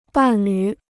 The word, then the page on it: 伴侣: bàn lǚ